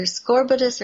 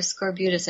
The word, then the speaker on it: scorbutus: And what the soldier died of was supposed to have been what was called either scorbutus or scorbutus. I've heard both pronunciations.